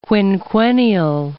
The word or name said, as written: quinquennial